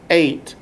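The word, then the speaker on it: eight (את): Its seven Hebrew words are balanced around this amazing word, the Aleph and the Tav, pronounced eight, as in the number eight.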